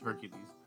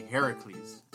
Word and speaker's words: Heracles: That's the Roman pronunciation is Hercules. The Greek pronunciation is actually Heracles,